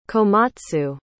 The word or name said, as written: komatsu